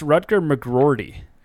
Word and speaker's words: Rutger McGroarty: By the way, Rutger Oh, you've been saying it wrong. It's Rutger McGroarty. There's two R's. – Sorry. – Rutger McGroarty.